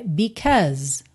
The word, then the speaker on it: because: Because, atenção aí, especialmente quem é iniciante, a escrita dessa palavra tem AU no meio, B-S-A-U-S-E, mas você não fala because, é because, because. Então, percebe que esse AU no meio tem um som a, e o E final não é pronunciado, ok?